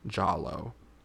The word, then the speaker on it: Giallo: G-A-I-L-O? – J-A-H-L-O. Giallo. It is a type, so Giallo.